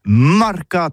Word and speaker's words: marcats: Mais en Gascogne toulousaine, c'est prononcé sur les marcats, avec un A.